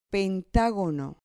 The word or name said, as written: pentagono